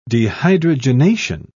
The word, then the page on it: dehydrogenation: British English